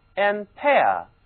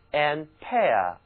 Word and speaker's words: ampere: 物理中啊，电流的单位是安培 ampere，发音的时候嘴型非常的扁 ampere，因为这是一个法语发音。安培是法国著名的物理学家，为了纪念他才用他的名字来命名电流的单位，这就是安培 ampere。